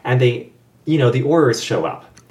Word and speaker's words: Aurors: Aurors.